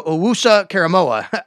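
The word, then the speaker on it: Owusu-Koramoah: Jeremiah Owusu Koramoah out of Notre Dame, I know a lot of you really want and think that he can fit out.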